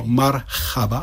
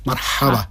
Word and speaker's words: marhaba: Taky není to marhaba, ale ha, marhaba.